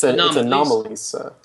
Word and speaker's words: Anomalisa: It's Anomalisa, right? – It's Anomalisa, yeah. – Yeah, yeah. Like an anomaly, right? – Whose name is Lisa?